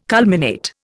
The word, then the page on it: culminate: Indian